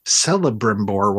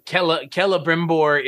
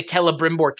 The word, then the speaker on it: Celebrimbor: The only thing that I never realized about this character is that it is a hard C rather than a soft C, because I used to always call him Celebrimbor when I was reading it. – Yeah, Celebrimbor is...